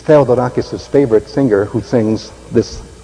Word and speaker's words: Theodorakis: His name is spelled Theodore, and then drop the final E and just add A-K-I-S, Theodorakis.